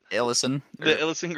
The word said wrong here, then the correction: Elysian: Grove? – Elysian Grove? That makes more sense.